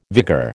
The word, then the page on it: vigor: American English